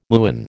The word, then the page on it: muon: American English